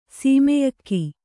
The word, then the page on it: ಶಮ್ಯಾಕ: śamyāka